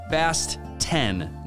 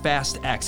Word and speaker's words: Fast X: Just so we're all aware, the director of the upcoming Fast and Furious movie wants us all to know that it's pronounced Fast 10, not Fast X. It's written as Fast X. They're using the Roman numeral. He said it's supposed to be a pun, as in Fast 10 your seatbelts.